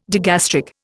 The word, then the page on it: digastric: Indian